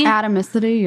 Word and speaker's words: atomicity: And they're ACID compliant, which means, I don't know how to pronounce this, but atomicity? – Atomicity, yeah. – Atomicity? It's like atom with an icity.